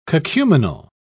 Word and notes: cacuminal: British English

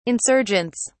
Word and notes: insurgents: British English

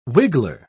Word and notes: wiggler: British English

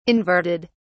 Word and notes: inverted: British English

